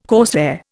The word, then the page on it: corsair: Indian